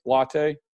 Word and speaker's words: Lotte: Am I saying that right? Lotte. – Okay. Yeah, okay. That's fine.